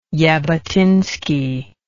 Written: jabotinsky

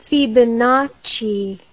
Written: Fibonacci

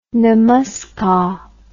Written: namaskar

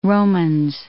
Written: Romans

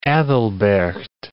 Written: Aethelberht

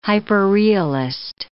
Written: hyperrealist

